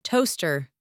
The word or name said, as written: toaster